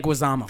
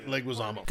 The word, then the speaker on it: Leguizamo: I am gonna go with John Leguizamo. I can never say it right. – Leguizamo. – Thank you. John Leguizamo.